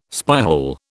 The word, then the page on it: spyhole: British English